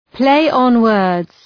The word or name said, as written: play on words